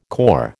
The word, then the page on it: corps: American English